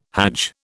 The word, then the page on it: hadj: British English